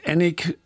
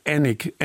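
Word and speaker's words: Alnwick: Da muss ich Ihnen gleich noch sagen, das spricht man ganz anders aus, als man es schreibt. Also wenn Sie es suchen sollten, wird geschrieben A-L-N und dann Wick. Annick, Annick Castle.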